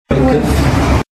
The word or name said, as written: Keufs